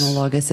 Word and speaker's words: analogous: The theme for Meat for Tea is always analogous. That just came out of my mind. I'm tired. Analogous. Oh my God. That was such a weird accent on the wrong syllable.